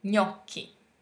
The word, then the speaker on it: gnocchi: Now, what about gnocchi? – Gne, o, chi. Gnocchi. – Gnocchi. – Gnocchi. Yes, right.